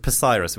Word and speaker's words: Psyrus: We were called Cyrus with a silent P. At the front, it was Psyrus.